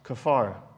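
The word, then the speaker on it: Kafar (כפר): Kafar. Actually, that's exactly it. It's kind of like Jafar from Aladdin, but with a K.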